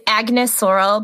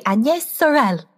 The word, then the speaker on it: Agnès Sorel: But literally, okay, for all of us like gross Americans, it's Agnès Sorel, but I'm going to be saying Agnès Sorel because that's how they pronounce it.